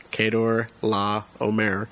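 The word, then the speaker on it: Chedorlaomer: Thus, after the battles against the eastern kings, especially the victory given to Abram by God over Kedor Laomer, I did research. That is how you say that name, Kedor Laomer, the leader of the eastern kings.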